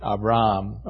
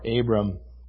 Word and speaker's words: Avram: I think that would probably be the way that the Hebrews would pronounce it, Avram or Abram.